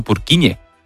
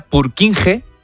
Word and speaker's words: Purkinje: Es que se escribe, portugués. En español sería Purkinje. – Purkinje. – Pero Purkinje es en checo, que es porque es el apellido del descubridor.